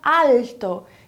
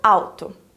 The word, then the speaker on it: alto: Por exemplo, a palavra alto, que se escreve igualzinho em português e significa a mesma coisa também, é pronunciada alto e não alto.